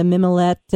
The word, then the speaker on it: mimolette: You mentioned the mimolette and mac, and you know how beautiful that would be.